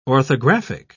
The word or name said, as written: orthographic